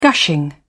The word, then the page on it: gushing: British English